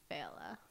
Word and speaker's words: Failla: Unless you want to say it all American-like, then it's Failla.